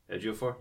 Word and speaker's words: Ejiofor: I mean, you have Donald Glover, you have James Earl Jones, you have Eric Andre, you have Chiwetel Ejiofor. Ejiofor. I tried to like they had the pronunciation on there, but he was Carl Mordo. – Yeah, he's Scar. Yeah. Beyonce is Nala. – Ejiofor. – Ejiofor.